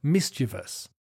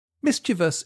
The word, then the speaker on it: mischievous: Clever and mischievous. Mischievous is a difficult adjective to pronounce. Mischievous.